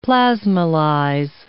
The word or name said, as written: plasmolyze